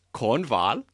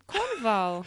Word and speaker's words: Cornwall: And I was singing about England in German, because I'm rehearsing Tristan und Isolde, which is set in Cornwall, which we pronounce Cornwall. – Cornwall. – Which I think is ridiculous. Why can't we just say Cornwall?